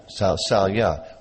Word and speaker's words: Shalya: Is it Shalya, right? Yeah. Shalya, who was Yudhishthira's uncle, who earlier, by Duryodhana's trick, was fighting for Duryodhana against the Pandavas.